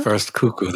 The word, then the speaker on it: First Cuckoo: First Cuckoo, that's correct.